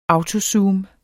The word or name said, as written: autozoom